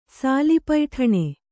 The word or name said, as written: ಸಾಲಿಪೈಠಣೆ